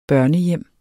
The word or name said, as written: børnehjem